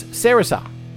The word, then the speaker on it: Sarissa: And the other track that I played for you, which I mispronounced, it's Sarissa. S-A-R-I-S-S-A.